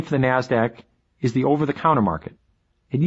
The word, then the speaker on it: NASDAQ: Today's big two stock markets are the New York Stock Exchange, NYSE, and the NASDAQ, which is pronounced NASDAQ.